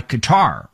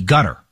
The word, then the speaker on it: Qatar: And what's noteworthy here is the agreement was reportedly brokered by Qatar, or as it is pronounced in D.C., gutter.